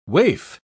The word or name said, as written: waif